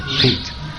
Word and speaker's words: pique: Miffed or pick, P-I-Q-U-E. Pick. How do you say that? How do you pronounce that? Peaked. He was peaked.